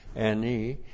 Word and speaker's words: ani: Aleph, A-N-I, long I, ani.